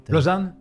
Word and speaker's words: Lausanne: Lausanne? – Yeah.